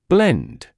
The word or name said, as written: blend